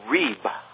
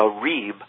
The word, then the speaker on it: rib: In this case, it is a technical Hebrew term delineating what's called a reeb, R-I-B, with a long E sound, a reeb, or a ritual covenant complaint from the judgment to follow.